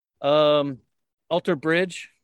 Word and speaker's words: Alter Bridge: So I listen to Disturbed, Pillar, Alter Bridge.